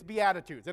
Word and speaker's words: Beatitudes: I pronounced it Beatitudes or Beatitudes. It's Beatitudes, and it comes from a Latin word, so I told you Latin was going to come in handy the other day.